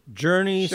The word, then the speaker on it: Jurnee: So you have the character of Judith, played by, I'm going to say her name wrong, Journey Smollett? – Journey, sure.